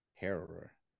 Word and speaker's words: Harrower: I can't say it. H-A-R-R-O-W-E-R. Harrower. Harrower? – The Herald? – No, Harrower. Harrower, yeah. – Harrower.